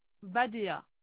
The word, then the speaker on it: Badea: Dama, D-A-2-M-A. – D'accord. Et le prénom ? – Badea. – Ça s'écrit comme ça se prononce ? – Oui, B-A-D-E-A.